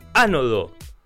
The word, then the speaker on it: ánodo: Hola, dice, al termotanque se le cambia el ánodo, con acento en la A.